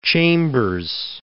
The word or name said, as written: Chambers